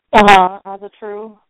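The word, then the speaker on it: Ásatrú: You know, a lot of Astrio, or Astrio, is that how it's said? Astrio? – Azatru.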